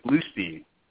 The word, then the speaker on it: Blustein: Is it Bloomstein or Bloomstein? – It's actually Bluestein.